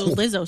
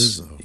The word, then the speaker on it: Lizzo: There's a lot of Izzles in a new Lizzo song. – Mm. – Yeah. – Oh, yeah, Lizzo's huge. – Well, it rhymes with Izzo, so Lizzo shizzle.